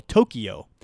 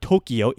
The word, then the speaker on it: Tokyo: I used to get really annoyed at people who used to pronounce Japan's capital, Tokyo. It's not Tokyo, it's Tokyo. There's no Tokyo anywhere in there, it's Tokyo. When I hear Tokyo, it used to annoy me. It doesn't annoy me as much anymore. Because I know in the English lexicon, it's Tokyo, and when I speak to a native Japanese speaker, it's Tokyo. That's simply how it's pronounced.